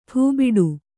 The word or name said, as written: ಠೂಬಿಡು